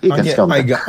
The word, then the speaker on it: Iga Świątek: Iga Świątek.